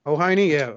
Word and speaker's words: Hoehne: H-O-E-H-N-E. How do you pronounce that, Robert? You're the attorney. – Say it again. – H-O-E-H-N-E. – I don't know. – Hohini, yeah. Okay, so Starla wrote...